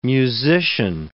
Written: musician

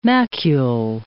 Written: macule

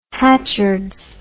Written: hatchards

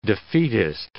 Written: defeatist

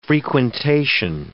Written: frequentation

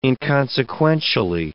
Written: inconsequentially

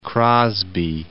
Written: Crosby

